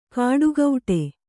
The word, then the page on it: ಕಾಡುಗೌಟೆ: kāḍu gauṭe